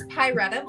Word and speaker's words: Pyretta: Yep, it's Pyretta Blaze.